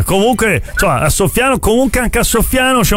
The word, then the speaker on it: Soffiano: Ma insomma, se scambi l'accento viene fuori un verbo, no? – Cioè, soffiano, esatto.